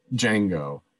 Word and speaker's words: Django: And he's like, Django. Can you spell it? And he spells like DJ. The D is silent.